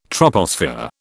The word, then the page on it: troposphere: British English